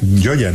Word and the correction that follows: Chioggia: no sé pronunciarlo. – Gioia. Chioggia. Chioggia, como dicen ellos, Chioggia.